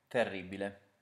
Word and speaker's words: terribile: Terribile.